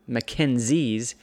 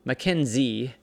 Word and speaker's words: MacKenzie: It's not a Z, and it was put in there for different purposes, but like, oh, MacKenzie. And so now because of the way it's spelled and this letter that gets misunderstood, we're pronouncing it as a Z, and now we've got a name of MacKenzie.